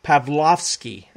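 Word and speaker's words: Pawlowski: I spoke about this with Pastor Arthur, I was saying his name wrong, Pavlovsky. I'm supposed to say it that way.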